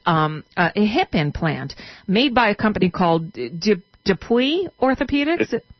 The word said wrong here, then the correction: DePuy: It's pronounced Depew. – Depew. Okay. Well, that makes sense. – Although sometimes I say Depuy. – But it is Pew.